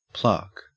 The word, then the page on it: plaque: Australian English